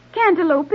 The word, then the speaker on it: cantaloupe: Cantaloupe is the proper designation for this form of muskmelon, though your pronunciation of the name leaves something to be desired. – Okay, what is it? – Cantaloupe, the name being derived from the castle in Europe where this melon was first grown.